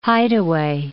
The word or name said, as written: hideaway